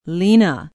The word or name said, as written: lena